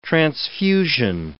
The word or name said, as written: transfusion